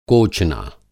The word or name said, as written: कोचना